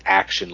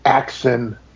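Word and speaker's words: Axxon N: I don't know if you read it this way, but there's also the black door that Laura Dern sees that is, it's spelled like AXXON, and then N, it's like the name of a person, but I always read it as action, like filming of, like you'd say action, and then they'd start the scene.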